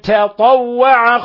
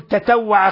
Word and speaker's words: تطوع: وإنما الصحيح فمن تطوع خيرا. فلا تكن أعجميا فتقول تتوع خيرا، ولا تكن يعني جاهلا فتقرأ فمن تطوع، تطوع بأن تجعل طاءين، التاء تجعلها طاء والطاء التي بعدها فتنطق بطاءين. وإنما فمن تطوع خيرا، خيرا فإن، تنوين فتح جاء بعده حرف الفاء، الحكم التجويدي إخفاء حقيقي.